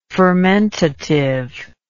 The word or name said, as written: fermentativeness